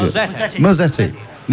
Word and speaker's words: Mazzetti: It was donated by Mazzetta? Island Limited, I think. – Mazzetti. – Nearly had it right. Italian, must be Italian.